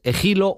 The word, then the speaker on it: Égilo: Y también hay una mujer que es Egilo o Égilo, como es un poco la pronunciación correcta, Daniel.